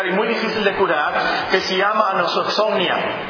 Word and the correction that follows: anosognosia: Se escribe A-N-O-S-O-G-N-O-S-I-A, anosognosia.